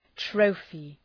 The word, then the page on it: trophy: ‘trəʋfı